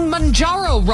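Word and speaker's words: Mounjaro: Believe it or not, you might be saying Manjaro wrong. That's according to a new list of the most mispronounced words of 2025, according to Babbel.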